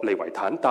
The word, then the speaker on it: 利維坦: 叫做利維坦。可能弟兄姊妹就會覺得，喂，聖經裡面哪有講過利維坦這件事啊，是吧？其實這個詞是一個希伯來文，它在舊約裡面出現過 7次，希伯來文讀作利維坦，但是和合本聖經將它翻譯為什麼？